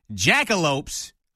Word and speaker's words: Jackalopes: The new Director of Broadcasting and Media Relations for the Odessa Jackalopes.